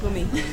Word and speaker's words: Brummy: Brummy. It's my nickname because I'm like a car, but I'm not a car. And in German, like, kids say brum brum to a car.